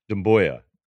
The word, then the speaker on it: Sekou Doumbouya: Then their best assets on their roster are Luke Kennard and Sekou Doumbouya. Is that how you say his name? First round pick? It's about right. – Yeah. He was their lottery pick this year. Doumbouya.